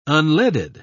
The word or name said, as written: unleaded